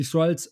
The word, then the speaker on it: Skrulls: Ähm, da geht es im Prinzip darum, dass äh, äh die Skrulls, jetzt habe ich es richtig gesagt, die Skrulls ähm, ja die, die Erde invaden, angefangen mit She-Hulk, wenn ich mich recht äh, erinnere.